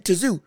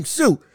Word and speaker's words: Tszyu: Also, somebody in the comments told me, because again, y'all have corrected me, y'all have corrected me before with me saying Tim Tazoo when it's actually Tim Tszyu. I apologize for that. I haven't forgotten it. Tim Tszyu. I got it now. I got it now. Tim Tszyu. Understandable. I, apologize for saying it wrong.